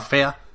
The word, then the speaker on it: Fe: Los del Fe, que se pronunciaba Fea, nos dijiste, Marta.